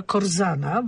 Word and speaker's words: Korzana: To jest podobno główny zarzut Tomasza Korzana, bo tak się wymawia jego nazwisko.